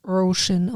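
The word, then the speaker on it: Róisín: MacGyver gelesen, Roisin. – Ist ein irischer Frauenname. Und wird Roisin ausgesprochen.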